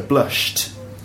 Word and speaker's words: blushed: Blush, well, it ends in a sh sound. So, of course, the pronunciation of the ed ending of that word is t. So blushed.